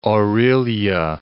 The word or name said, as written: Orillia